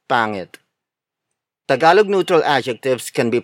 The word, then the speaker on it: pangit: For example, pangit, pangit, pangit. Pangit is spelled as P-A-N-G-I-T.